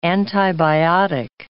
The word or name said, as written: antibiotic